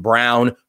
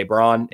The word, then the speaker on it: Braun: So I don't know if either of you guys follow KU particularly closely, but they have Christian Brown, whose name is spelled Braun. If I am thinking about him, I'll say Christian Brown. But if I'm reading his name, I'll say Braun every time.